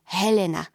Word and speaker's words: Helena: Chcela som sa volať Marigold, lebo tak sme mu vtedy hovorili – Ostala mi však len Helena, pričom musím často vysvetľovať, že sa vyslovuje s mekým ľo Helena, ako o mnohých iných veciach, aj o tomto rozhodol otec.